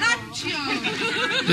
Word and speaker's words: Accio: Like it's Italian. – Accio in Italian. Accio.